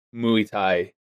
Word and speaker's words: Muay Thai: Is that how it's pronounced? Muay Thai? – Muay Thai. – Not Muay Thai? Muay Thai? – I literally made sure I've triple check. It's Muay Thai.